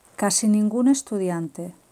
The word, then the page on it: casi ningun estudiante: Spain